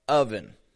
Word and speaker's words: oven: Let's start with a word that I notice people all over the world mixing up, oven. O-V-E-N. It's spelled with an O, but it's pronounced with an a short U, sound.